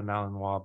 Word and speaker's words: Malinois: And so the word is a French word, so it's spelled Malinois. The French, in French, you say Malinois, right? A lot of people videos that say Malinois, which is not Malinois.